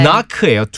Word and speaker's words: knock: 자, 여러분도 아시겠지만 K와 N과 함께, 어, 함께 있으면 크느가 아니라 음. K 소리가 안 나고 음. N 소리가 납니다. – 네. knock예요. – 두드리다. 문을 두드리는 것처럼 두드리다라고 하고 on wood. W-O-O-D.